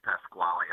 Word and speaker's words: Pasquale: It's definitely Pasquale. I'm not Spanish whatsoever.